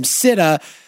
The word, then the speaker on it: SITA: Customs and Border Protection, CBP, and I think the company's called CITA, S-I-T-A. You pronounce it however you like. I call them CITA,